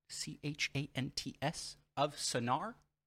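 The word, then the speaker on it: Chants of Sennaar: And the nominees are Chance of Sinnar. That's Chance. Chants of Sinnar.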